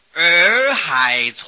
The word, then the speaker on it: 洱: 观世音菩萨现的比丘身很多的，有一部书叫做《洱海丛谈》，耳朵的耳旁边加过三点水那个耳，音是相同的，叫做念作儿子。洱海呀、啊，就是现在的昆明池，云南的昆明池，从前叫做洱海，这部书是云南人做的了，因为靠近洱海呀、